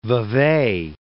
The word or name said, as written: Vevey